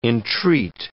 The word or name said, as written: entreat